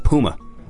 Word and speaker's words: Puma: Yeah, Puma.